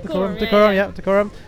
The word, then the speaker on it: Dacorum: Not many people might know this, but you were born in Dacorum, is that pronounced right? – Dacorum, north of London.